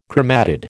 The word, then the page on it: chromatid: American English